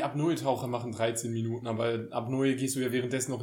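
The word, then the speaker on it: Apnoe: Wie wird das ausgesprochen? – Abneu.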